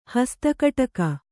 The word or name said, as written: ಹಸ್ತಕಡಗ